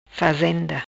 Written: fazenda

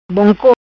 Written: Bonco

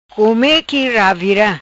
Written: Come Qui Ravira

